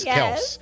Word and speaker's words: Kelce: By the way, if you don't know what we're talking about, the dad didn't change the name. That's just how you pronounce it. The name is Kelce.